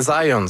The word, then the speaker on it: Zajonc: Non, c'est Zajonc. – Ouais, OK. – J'ai-tu pas dit Zajonc? – Vous avez dit plus Zajonc, mais. – Parce que j'ai prononcé un petit peu à l'anglais. J'ai prononcé mon polonais à l'anglais.